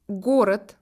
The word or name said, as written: ville